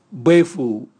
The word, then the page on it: bwevu: Raga